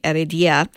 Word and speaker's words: Heredia: The Spanish monk Sebastian Aguilera de Arida, I pronounced that wrong, Heredia, worked as an organist at the cathedral in Huesca from 1585 to 1603.